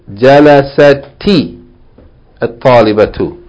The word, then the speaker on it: جَلَسَتِ الطَّالِبَةُ: The reading of it should be read جَلَسَتِ الطَّالِبَةُ. Yani the sukoon on جَلَسَتْ, last letter ta, the sukoon should be pronounced with kasra.